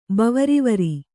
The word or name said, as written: ಬವರಿವರಿ